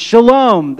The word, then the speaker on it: shalom: It's shalom. That's how you say it.